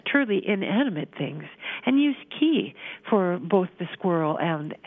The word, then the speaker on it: ki: And I've recently been writing and thinking about this, and the proposal for another pronoun is to use pronoun K-I, pronounced ki.